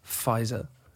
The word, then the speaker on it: Pfizer: I had my second Pfizer. – Pfizer? – I thought for a while it was the Pfizer with a V. – Mm-hmm. – It's a silent P.